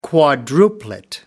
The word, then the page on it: quadruplet: American English